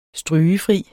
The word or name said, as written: strygefri